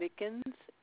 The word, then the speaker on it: Mickens: It's spelled Y, V as in Victor, O-N-N-E. Last name Mickens, M-I-C-K-E-N-S.